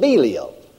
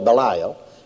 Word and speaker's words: Belial: Now, and what concord hath Christ with Belial? We call it Belial. Actually, the correct pronunciation is Belial.